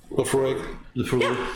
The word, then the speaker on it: Laphroaig: Am I saying that right? Laphroaig. – Yeah, you're saying it right. – I'm saying it right. – Perfect. – There's a float of Laphroaig on top of this, which just adds that little bit of smokiness to it, and that totally complements the Latakia.